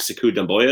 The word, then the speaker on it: Sekou Doumbouya: Uh, have some good young pieces, uh, like Sekou, I think Sekou Doumbouya is how you pronounce his name.